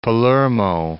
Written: Palermo

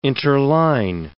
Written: interline

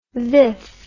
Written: viff

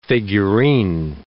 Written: figurine

